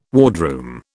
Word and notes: wardroom: British English

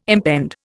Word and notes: impend: Indian

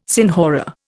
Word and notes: senhora: Indian

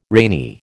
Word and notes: rainy: American English

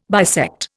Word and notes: bisect: Indian

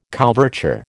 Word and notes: couverture: American English

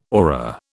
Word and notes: aura: British English

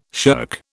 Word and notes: shirk: British English

